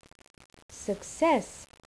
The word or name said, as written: success